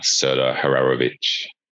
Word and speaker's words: Hararovich: Actually, I'll start by just saying, how do you pronounce your name so I'm saying it correctly? – It's Serdar Hararovich.